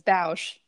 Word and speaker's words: Bausch: It actually rhymes. It's Bausch.